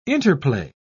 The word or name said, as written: interplay